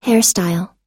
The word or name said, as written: hairstyle